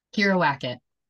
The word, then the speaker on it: Kyira Wackett: Kyira Wackett.